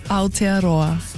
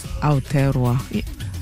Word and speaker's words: Aotearoa: And their assistant curator who's come all the way from, help me guys, make sure I say this correctly, Aotearoa. – Aotearoa.